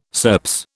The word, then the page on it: SERPS: British English